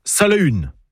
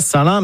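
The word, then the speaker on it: Salaün: En breton, on prononce Salahun. Pas Salin, mais Salahun.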